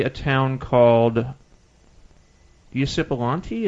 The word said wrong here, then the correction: Ypsilanti: Is that how you say it? – Ypsilanti, yeah.